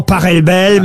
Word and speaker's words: Pachelbel: S'il est allemand, ça se dit Pachelbel. – Exactement, ouais. Ça pourrait se dire éventuellement Pachelbel, mais on dit en tout cas, ici en France, le canon de Pachelbel.